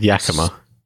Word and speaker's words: Yakima: Yeah, Yak, Y-A-K-I-M-A, Yakima.